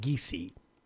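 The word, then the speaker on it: Giese: Her name is pronounced Geese. Gianna Geese.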